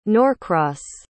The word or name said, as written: norcross